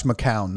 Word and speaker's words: McCown: Do you think, you know, the rumors are they're going to hire McGowan. Do you really think they would do something like that? – Josh McCown, not McGowan.